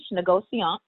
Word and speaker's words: négociant: So what is a négociant? It's French, négociant.